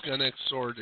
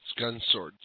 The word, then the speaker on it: Gun X Sword: Yes, one thing though, it's not pronounced Gun X Sword, it's Gun Sword. The X is always silent.